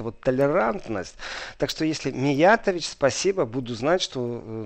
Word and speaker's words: Миятович: что в принципе правильное ударение Э, поставить на другой слог, что это не э, Миятович, не Миятович, а Миятович, что правильно говорит Дуня Миятович, что это типично для, для меня это югославская фамилия.